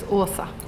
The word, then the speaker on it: Åsa: How do you pronounce Asa? There's a little O on top of one of the A's. – So it's Åsa.